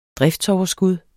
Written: driftsoverskud

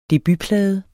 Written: debutplade

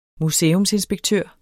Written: museumsinspektør

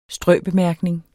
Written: strøbemærkning

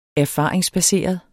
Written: erfaringsbaseret

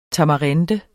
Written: TAMARINDE

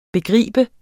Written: begreb

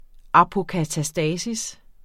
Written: apokatastasis

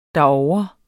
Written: derovre

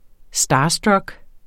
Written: starstruck

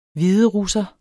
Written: hviderusser

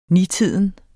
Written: nitiden

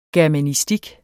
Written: germanistik

